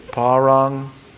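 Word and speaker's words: Parangelo (παραγγέλλω): Do you have something like parangelo? You got that? Okay. See that double G? That's pronounced like NG. That's why I said pa, and there should be an A in there. Parangelo.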